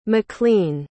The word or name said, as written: maklean